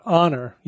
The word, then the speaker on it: honor: What about honor? You don't pronounce the H in honor.